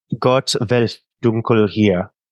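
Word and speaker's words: Gott, welch Dunkel hier: Gott, is it Gott welch dunkel air? How do you say that? – Gott welch dunkel hier.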